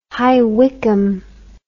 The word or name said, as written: High Wycombe